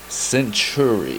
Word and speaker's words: Syntury: Did you just say Synergy? – I can't remember. – Pause. Rewind the tape. It's false advertisement already. – Give me the correct pronunciation. – The correct pronunciation is Syntury.